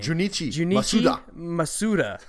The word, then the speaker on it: Junichi Masuda: Junichi Masuda.